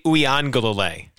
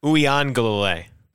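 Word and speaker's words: Uiagalelei: But it's DJ Uyangalele. – I was close. – You were two syllables off. – Yeah, Galele. Say that again. – Uyangalele.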